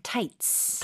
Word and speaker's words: tights: 영어로는 치 발음으로 절대로 하시면 안 되고요. 그냥 tights. – Tights. – Yeah. – 타이츠가 아닙니다.